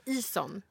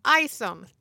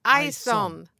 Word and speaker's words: Ison: Det är fel att säga Ison. Han heter Ison. – Tack. Ison. Men det är ganska förståeligt med tanke på själva i i början och själva ut, äh, eh, svenska uttalet.